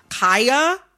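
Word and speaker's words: Khia: I am going to, in this conversation, pronounce Kaya, the rapper, with the long I. Kaya. Okay, my neck, my back, mm-hmm. for those who need a refresher. Kaya, okay? Mm-hmm. The Kia Asylum is a mispronunciation of her name.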